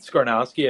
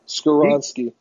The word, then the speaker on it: Skoronski: I will say, I guess another takeaway from that game is that Pete Skarnowski, I think that's how you pronounce his last name, Big 77. – Skoronski.